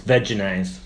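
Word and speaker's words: Vegenaise: Can I stop you there for a second? – Mm-hmm. – It's veganaise. – No. – Yes. – No. – You have to say... – I've been saying it wrong for like 6 years.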